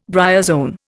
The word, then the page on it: bryozoan: Indian